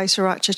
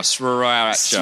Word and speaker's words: sriracha: It's very, very important to pronounce that first R, sriracha.